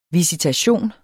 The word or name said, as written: visitation